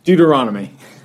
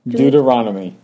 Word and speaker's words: Deuteronomy: Deuteronomy. Deuteronomy.